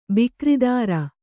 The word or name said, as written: ಬಿಕರಿದಾರ